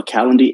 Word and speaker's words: Calendly: It's spelled C-A-L-E-N-D-L-Y, so Calendly.